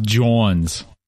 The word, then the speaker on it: Johns: It's pronounced Johns. – Okay. – You're from Philly. You should know that.